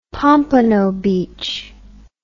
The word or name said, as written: Pompano Beach